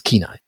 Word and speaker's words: Kenai: He pronounced it Kenai and I thought, okay, this is, nobody asks somebody from Alaska about that. – Right. – Because it is Kenai.